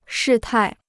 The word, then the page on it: 事态: shì tài